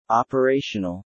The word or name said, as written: operational